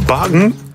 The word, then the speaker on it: Bakken: Bagen? Zo wordt het dus blijkbaar uitgesproken in het Deens.